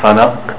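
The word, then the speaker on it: فلق: Falaq. Good. Do you see that qaf? That's called qalqala. Vibration of the qaf. Not falaq. Falaq.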